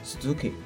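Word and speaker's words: 鈴木: 以日本第二大姓氏铃木为例，同样读作 Suzuki，尚有寿寿木、铃树等八种。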